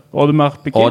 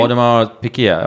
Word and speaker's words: Audemars Piguet: Hur uttalar man AP på riktigt? – Jag vet inte. Audemars Piguet. – Audemars Piguet. Ja, men hur fan ska man kunna uttala det? – Du får ha den franska.